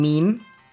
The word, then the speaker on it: Meme: Ein Meme, ausgesprochen Meme, Mehrzahl Memes, ist ein spezieller, kreativ geschaffener Bewusstseinsinhalt, der sich unter Menschen verbreitet.